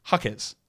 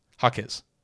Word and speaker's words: Jaquez: Thank you to everyone who responded to me asking, hey, how do I pronounce Jaquez? Is it actually Jaquez or is it Jaquez? Or is Jaquez or Jaquez? It's Jaquez. Jaquez. Which is what I said originally.